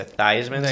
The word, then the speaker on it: Theismann: Old, I forget when he played, 70s, 80s, but in college, supposedly his last name is pronounced Theismann, but he changed his pronunciation to Theismann, so it rhymed thighs. – With Heisman.